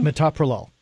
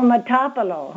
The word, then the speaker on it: Metoprolol: I wanted to ask if I'm on, I think it's Metoprolol, you pronounce it, 50 milligrams? – Metoprolol. – Oh, Metoprolol. – Yes.